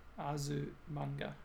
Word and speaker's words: Azumanga: Asumanga Daio, for example. – Mm. I don't remember that one. I remember the name. How do you spell it? – Azumang. Azumanga. – Ah, there it is.